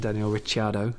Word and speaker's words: Daniel Ricciardo: at Red Bull with Max Verstappen and Daniel Ricciardo.